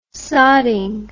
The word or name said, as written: sodding